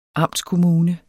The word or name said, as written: amtskommune